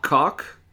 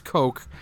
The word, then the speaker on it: Koch: Cock. – Yeah. – Just want to point that out. – I think it's pronounced Coke. – Oh, okay. – Like the Coke brothers, the Republican scumbags.